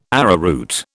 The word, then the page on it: arrowroot: British English